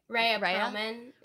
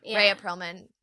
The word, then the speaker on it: Rhea Perlman: Rhea Perlman. – Rhea Perlman.